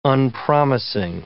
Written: unpromising

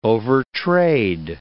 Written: overtrade

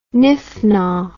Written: niffnaw

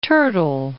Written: Turtle Bay

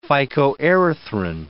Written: phycoerythrin

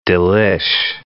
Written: delish

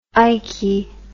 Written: iky

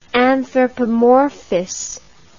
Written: anthropomorphous

